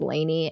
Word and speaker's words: Lani: And she sees that Jade is live with Santi and her name is spelled L-A-N-I, but it's Lainey, and it upsets me. I want to say Lonnie.